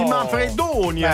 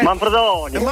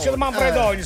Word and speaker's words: Manfredonia: Oh, di Manfredonia, beh, no Manfredonia. – Dillo bene la pronuncia, dai. Com'è che è? – Manfredonia. – Manfredonia, sì.